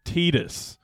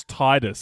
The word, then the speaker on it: Tidus: the main character's name is Tidus, and everyone thought his name was Tidus because it's never pronounced.